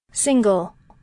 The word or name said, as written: single